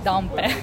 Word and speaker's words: Dompé: D-O-M-P-E with a apostrophe. – Yes. So it's pronounced Dompé. Okay. Dompé in Italian, but in English, Dompé.